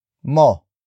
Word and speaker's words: mo: The next rule is that the ba particle can come after the pronoun mo.